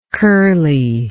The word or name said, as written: curliness